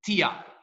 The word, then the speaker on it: Tiart: Okay, so you pronounce it Tiart. – Tiart, okay. – Yeah, so the best way to explain it is the letter T and a heart.